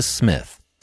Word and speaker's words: Psmith: Wodehouse and is the beginning of the novel Leave It to Smith, The P is Silent, part two of the Blandings Castle series.